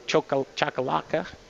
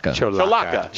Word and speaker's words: Cholaca: Cholaca. – Cholaca.